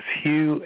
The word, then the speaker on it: Hugh: My name is Hugh, H-U-G-H, the last name Charlson, that's spelled T like Tom, R-A-U-L,